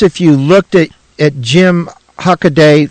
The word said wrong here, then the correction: Hockaday: through a Hockaday. I always say it wrong, Hockaday.